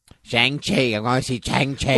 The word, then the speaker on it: Shang-Chi: shang-chi and the Legend of the Ten Rings. I'm going to say it like that because that's how they said it in the movie.